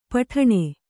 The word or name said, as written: ಪುಟಾಣಿ